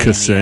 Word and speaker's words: Ksenia: Ksenia. – Yeah. – You don't want to put so much space between the K Ksenia. – And the S. – Ksenia.